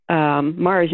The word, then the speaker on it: Mahrer: And his name is not spelled the way it sounds. So Mar's name sounds like, you know, the planet, but it's spelled M-A-H-R-E-R, Al or Alvin R. Mar.